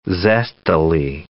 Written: zestily

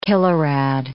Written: kilorad

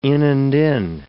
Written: in-and-in

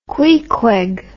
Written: Queequeg

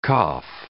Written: cough

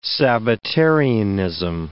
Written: Sabbatarianism

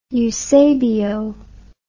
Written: eusebio

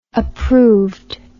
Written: approvedness

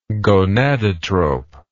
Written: gonadotrope

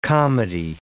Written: comedy

